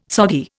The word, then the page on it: soggy: Indian